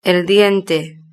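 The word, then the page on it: el diente: Spanish (Spain)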